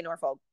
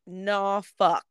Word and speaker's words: Norfolk: You can't say Norfolk. – Oh. – It is literally nah fuck. Like it's what? And I'm not saying that to cuss. Nah fuck. That's how they say it.